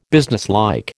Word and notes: businesslike: American English

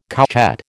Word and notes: couchette: American English

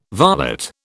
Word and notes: varlet: British English

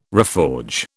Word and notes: reforge: British English

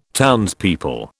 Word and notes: townspeople: British English

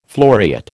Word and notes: floriate: American English

